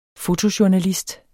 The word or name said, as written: fotojournalist